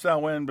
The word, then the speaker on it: Samhain: It's pronounced Samhain, but it's spelled Samhain festival.